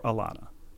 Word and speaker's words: Ilana: Cheers, Alana, I-L-A-N-A, resident Canadian. We've heard from Alana before. She adds a helpful postscript, pronounced as if it started with an E or an A. I accept both. My parents use both. So, Ilana. Or Alana. And yet it's spelled with an I.